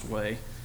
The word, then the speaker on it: Hue: It's actually spelled H-U-E, so it looks like Hugh, but it's pronounced Huey.